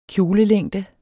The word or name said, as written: kjolelængde